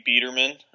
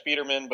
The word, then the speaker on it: Biederman: It's actually Biederman. Biederman. There's kind of a long story behind it, but I totally understand because nowhere in English does an I make a long E sound.